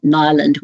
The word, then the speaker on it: Niland: So if you just go to A Darker Magic This Way Comes, or type in my name, Carmel Niland, which is spelled N-I-L-A-N-D, that will take you to where you can purchase it there.